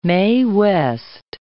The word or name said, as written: Mae West